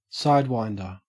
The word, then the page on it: sidewinder: Australian English